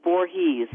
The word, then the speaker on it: Voorhees: How do we pronounce that? – You pronounce it as Voorhees.